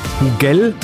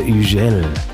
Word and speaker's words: Hugel: Die Franzosen, die sprechen das H ja nicht, ne? Also Hugel heißt der DJ, wenn ich es deutsch aussprechen würde. Aber weil er Franzose ist, heißt er Hugel.